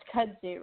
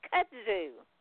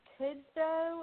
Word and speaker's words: kudzu: Like I was listening to something the other day, and they pronounced kudzu wrong. – How did they mispronounce kudzu? – I think it was kudzo or something, or I don't, and I don't even really know how to spell the word, so maybe the mispronunciation came from the spelling.